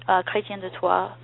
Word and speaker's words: Chrétien de Troyes: I also mentioned Cligès, which is written by the important romance writer Chrétien, Chrétien de Troyes,